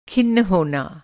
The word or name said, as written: खिन्न